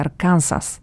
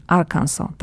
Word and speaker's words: Arkansas: że w Polsce wiele osób wymawia Arkansas, to jest Arkansas, tak mówimy, Arkansas, to Arkansas znajduje się nad Teksasem, ale nie bezpośrednio, tylko tak bardziej u góry po prawej stronie.